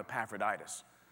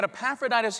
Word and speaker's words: Epaphroditus: Epaphroditus. We had a fun time with this name. There's a lot of great words that rhyme with Epaphroditus, like laryngitis and hepatitis.